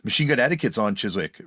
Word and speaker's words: Chiswick: I always mispronounce it as Chiswick, but Chiswick stuff.